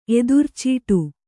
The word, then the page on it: ಎದುರ್ಚೀಟು: edurcīṭu